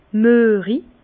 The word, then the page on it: müerig: Bas-Rhin; Herrlisheim